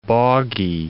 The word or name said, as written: boggy